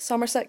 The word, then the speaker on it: Somerset: Um, Somerset. Is that how you say it? Somerset County in Pennsylvania. – Yeah, I think that's right.